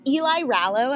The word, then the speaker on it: Eli Rallo: Is it Eli Rallo? Am I saying that right? – Yes.